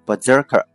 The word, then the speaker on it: Berserker: 在这里面提醒大家一下这个词的发音啊，虽然这里面写的是 S，但是呢它被浊化成 Z 的音啊，就是读成 Berserker 不是 Berserker。